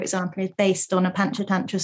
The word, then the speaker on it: Panchatantra: What is Panchatantra? Am I saying it correctly? – Yeah, Panchatantra is the most recent collection.